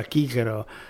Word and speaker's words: Cicero: Cicero eh, invece si, probabilmente si pronunciava Chichero,